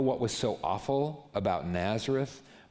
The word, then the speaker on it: Nazareth: That's how you pronounce it. Nazareth. It's like the facial, like, Nazareth.